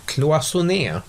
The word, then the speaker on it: cloisonné: Cloisonné. Eh, cloisonné blir på svenska cellemalj.